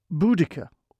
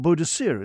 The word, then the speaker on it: Boudica: The woman in question being Boudica, or Boadicea, as we used to know her before we got all serious about correct pronunciations.